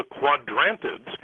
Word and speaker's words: Quadrantids: But the correct pronunciation of this meteor shower is called the Quadrantids.